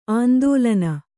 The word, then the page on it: ಆಂದೋಲನ: āndōlana